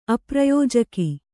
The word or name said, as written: ಅಪ್ರಯೋಜಕ